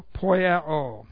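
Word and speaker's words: poieō: The works that I do, the present active indicative of the verb poiao. P-O-I-E long O. Poiao. Okay. Poiao. Oh, thank you.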